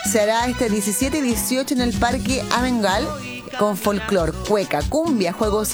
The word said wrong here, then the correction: Amengual: Bueno, Parque Amengual parece que lo dije mal, discúlpenme. Y de Pudahuel nos vamos a Maipú, en la Plaza de Maipú.